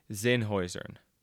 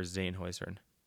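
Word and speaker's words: Zenhäusern: Then there's the 6'7" Swiss slalom skier, and it's pronounced Ramon Zinhuizen. There's a bunch of letters in that last name, but just remember Zinhuizen.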